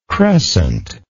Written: crescentic

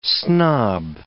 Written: snob

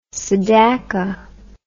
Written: Sedaka

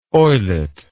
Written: oyelet

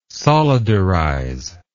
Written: solidarize

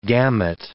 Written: gamut

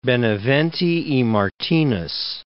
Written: Benavente y Martinez

